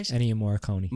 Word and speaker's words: Ennio Morricone: Ennio Morricone.